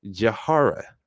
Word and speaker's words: Jahara: Um, please give it up for, uh, let me just make sure I'm saying this right, Jahara, right? – That's right.